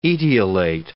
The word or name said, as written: etiolate